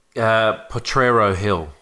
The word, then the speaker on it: Potrero: Well, indeed, we've heard their Portero. How do we pronounce it? – Potrero Hill.